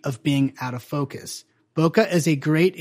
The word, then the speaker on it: bokeh: B-O-K-E-H is how it's spelled. Bokeh is how it's pronounced. We have evidence for it, at least, you know, the mid-1990s to early 2000s. And it's spelled, interestingly, because it ultimately comes from Japanese, bokeh.